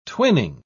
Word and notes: twinning: British English

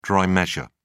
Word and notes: dry measure: British English

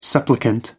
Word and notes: supplicant: American English

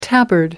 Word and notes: tabard: American English

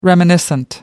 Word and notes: reminiscent: American English

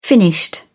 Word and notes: finished: British English

